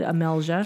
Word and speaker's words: Amelija: It's Amelia Duncan, spelled A-M-E. Am I saying this right? A-M-E-L-I-J-A Duncan. D-U-N-C-A-N. So go follow Amelia Duncan with a J, should be said Amelja.